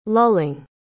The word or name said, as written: lulling